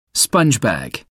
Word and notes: sponge bag: American English

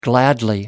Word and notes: gladly: American English

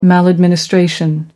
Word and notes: maladministration: American English

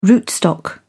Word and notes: rootstock: British English

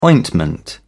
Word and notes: ointment: British English

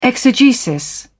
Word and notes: exegesis: British English

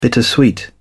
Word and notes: bittersweet: British English

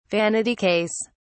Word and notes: vanity case: British English

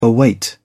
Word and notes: await: British English

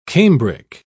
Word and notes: cambric: British English

